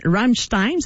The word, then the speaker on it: Rammstein: Et ce soir, c'est le métal qui est à l'honneur avec un hommage à Rammstein.